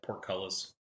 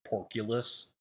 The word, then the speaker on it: portcullis: Is that word pronounced portcullis? Have I been saying it wrong? – No, it's port. It's like portcullis. – Okay. – The old joke was we had a card. The card was portcullis, and CJ Schrader, one of the former hosts, pronounced it porculus. Unironically.